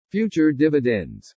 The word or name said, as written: FUTURE DIVIDENDS